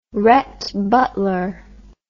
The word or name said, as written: rhett butler